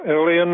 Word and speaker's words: Ilion: Lyon, New York, or Ilion, New York. Is that it? Am I saying that right? – Ilion, New York. You got it.